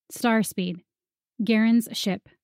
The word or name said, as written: Starspeed